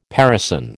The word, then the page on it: parison: American English